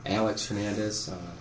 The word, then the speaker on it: Alex Hernandez: Alex Hernandez, A-L-E-X, H-E-R-N-A-N-D-E-Z.